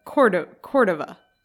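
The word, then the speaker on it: Córdova: But there's an accent on the first O. Cordova.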